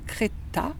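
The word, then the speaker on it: Crettaz: Donc, les cafés mortels, c'est une invention d'un ethnologue, sociologue, suisse, Bernard Creta, parce qu'il y a un Z au bout, mais ça ne se prononce pas, qui a énormément travaillé sur la mort, avec sa femme aussi.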